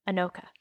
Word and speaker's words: Anoka: So on the main website for Anoka, and I looked up how to spell, like how to say this, so I know this is right. Anoka. – I heard it, and it's right.